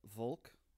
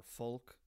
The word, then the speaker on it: Volk: Yeah, and it's called Volk, or Folk, depending on how accurate your German pronunciation is. It's spelled with a V, but it's kind of fudged between a V and an F.